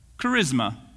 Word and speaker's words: charisma: This is exactly how you would pronounce it. Charisma.